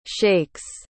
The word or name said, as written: shakes